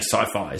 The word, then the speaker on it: Syfy: And yeah, S-Y-F-Y, the Sci-Fi Network. – Yeah. What S-Y, I guess. How do you say it? I'm saying Sci-Fi, is that right? – Yeah, that's how it's said, but there's...